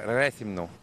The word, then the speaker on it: Réthymnon: J'ai entendu des prononciations euh, très très différentes, mais les Grecs vont dire Rethymnon.